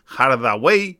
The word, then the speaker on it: Hardaway: Nuestro país es en 1950 nacía Stefan Hardaway Morris. Hardaway, ¿no? Sí, lo he dicho bien. Stefan Hardaway Morris, conocido artísticamente como Stevie Wonder.